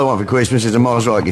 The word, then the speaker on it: Mosrite: Is a, I'm hoping I'm saying this right, a Mosrite guitar. Does that sound right? – Sounds right to me.